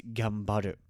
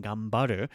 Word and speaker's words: 頑張る: gambaru because the n sound becomes an m sound.